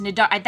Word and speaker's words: Cnidaria: Is it Cnidaria? C-N-I-D-A-R-I-A. – Wait, was it? – C-N-I-D... A-R-I-A. – No. I got some, look, I got some Latin lined up that I'm just gonna... – Nidaria? – Yes, Nidaria. That sounds right? Nidaria? – Okay, alright. Jellyfish are also members of the phylum Nidaria. We think that's how it's pronounced.